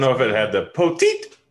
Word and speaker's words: Poteet: It's poteet. Um, that's what he's always going to be to me. I don't care how many people tweet me and tell me I said it wrong.